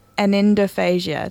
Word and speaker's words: anendophasia: You've also been looking at anendophasia.